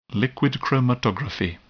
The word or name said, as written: liquid chromatography